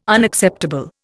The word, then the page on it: Unacceptable: Indian